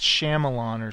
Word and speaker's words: Shyamalan: Oh, it looks like I said his name wrong. It's Shyamalan or something. Oh.